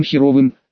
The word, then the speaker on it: Херов: У тебя же были неплохие отношения с этим, как его, партизаном Хреновым, с партизаном Херовым, фамилия у него такая была, и ударение правильно ставить на О, Херов.